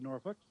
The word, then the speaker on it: Norfolk: And they'll say Norfolk. – Folk. Yeah, well, it's... I've always pronounced it Norfolk. – Well, that's what it is. It's Norfolk. – Yeah. – Or Norfolk. If you get a little further up east or across the pond.